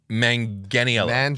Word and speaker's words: Manganiello: Joe, is it Manganiello? – I thought the G was soft. – Manganiello. – Soft G, not a hard G.